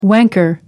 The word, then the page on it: wanker: American English